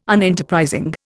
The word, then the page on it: Unenterprising: Indian